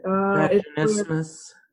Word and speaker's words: vaginismus: I have, oh God, how do you pronounce it? – Vaginismus. – Yeah, vaginismus.